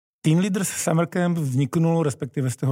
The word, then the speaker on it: Teen Leaders: Tak to Z-ko vlastně se ztratí, protože Teen Leaders píšeme se Z a odkazuje to vlastně k cílové skupině,